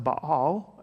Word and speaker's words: Baal: Well, Elijah has this battle between the prophets of Baal, is how you pronounce it in the Hebrew.